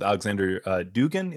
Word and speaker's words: Alexander Dugin: You know, I think you talk about this with Alexander Dugin, if that's how you say it. – Yes.